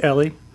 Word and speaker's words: Eli: Our butler, whose name was, it was spelled Eli, E-L-I, but he pronounced it Ellie.